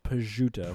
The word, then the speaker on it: prosciutto: Correctly pronounced, prosciutto.